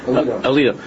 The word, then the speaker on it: Alito: You know, I read in the newspaper that the other day, how these menavolim put that Judge Alito, is that how you say his name? I never heard it. I only saw it in the paper. – Alito.